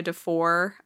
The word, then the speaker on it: Defore: My pen name is Daria Defore, uh, D E F O R E, not R T, unlike my real name. It's pronounced like it's spelled, and spelled like it's pronounced, unlike everything else.